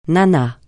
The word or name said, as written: nana